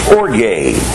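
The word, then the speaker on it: orge (ὀργή): The word wrath is from the Greek word pronounced orge.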